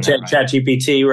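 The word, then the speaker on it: ChatGPT: I hope in 5 years they've conquered the challenge that we're seeing now with chat RPT. Am I saying chat GPT? Right? Yeah.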